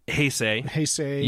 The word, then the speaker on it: Heisei: H-E-I-S-E-I? – Yeah.